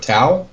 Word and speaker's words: Tau: Tau, I think it's how it's pronounced. T-A-U. – Yeah.